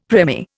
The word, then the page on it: premier: Indian